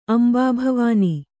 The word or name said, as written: ಅಂಬಾಭವಾನಿ